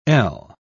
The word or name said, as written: ell